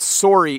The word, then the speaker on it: sorry: So the word sore and the word sorry have the same O sound when we pronounce them in Canadian English.